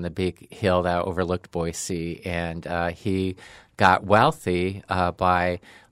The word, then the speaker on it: Boise: I did say it right, though, right? – Yeah. – Boise. – I Googled that, too. Very good. The S. you pass.